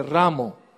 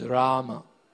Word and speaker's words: Rama: And he started to chant Hare Rama, then change it to Hare Ramo. And Prabhupada stopped the kirtan. And he asked him, who's this Ramo? You are ruining the movement. And Prabhupada corrected him. He said, the right pronunciation is Rama.